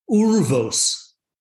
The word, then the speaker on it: urvos: But check out this pronunciation. Never would have guessed this in a million years. Urvos.